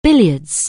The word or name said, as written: billiards